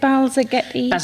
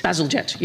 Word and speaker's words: Bazalgette: Sir Peter, I don't know how to say it, is it Balzaghetti? – Bazalgette, yeah.